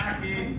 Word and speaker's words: Nike: How is that pronounced in English? – Nike.